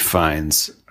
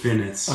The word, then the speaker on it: Fiennes: Colin Farrell and Colin Farrell and Academy Award nominee Rafe, not pronounced Ralph, Rafe Fiennes. – Not pronounced Finnis.